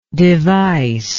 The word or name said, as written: devicefulness